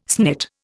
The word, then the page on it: snit: Indian